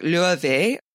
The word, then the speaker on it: Laufey: So her name is, it's spelled L-A-U-F-E-Y. She's like the best modern English would be like Lave. It would be really like Lave.